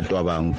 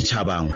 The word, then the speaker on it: Chabangu: Nanokuthi uhluleke ukupronounce igama likaChabangu bese ethi yena Twabangu, Twabangu. Igama eliqala elifana nelakhe maliqala ekukhulumeni, hayi ekuvaleni, ekukhulumeni. Yini into namhlanje zathi Twabangu engabuthi Chabangu ngoba nenaye uqala ngokuthi Chamisa, cha Chabangu, Chamisa.